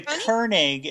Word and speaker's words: Koenig: I'm not sure you pronounced that right, but it is a Koenig. It is a German word for king.